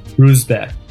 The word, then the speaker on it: Ruzbek: How do you say your last name? – Ruzbek. – Okay, so that's more like just how it's spelled then, right? – Yeah, it literally means good day.